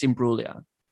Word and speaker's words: Imbruglia: Is it Imbruglia or Imbruglia? – I think the G is silent. I think it's just Imbruglia.